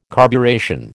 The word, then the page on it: carburation: American English